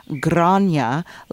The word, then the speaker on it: Grania: And it's a very common Irish name, Grania, like Dawn, with Ya at the end, Grania.